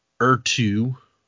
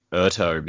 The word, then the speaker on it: Ertu: I always thought it was like Ertu because like the end looks like plateau. – Yeah, like a plateau.